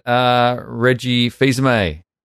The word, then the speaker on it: Reggie Fils-Aimé: And we've had Reggie Fils-Aimé.